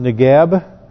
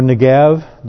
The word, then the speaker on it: Negev: You may hear the term Negev, or Negev, depending upon the kind of pronunciation, and it simply means south country.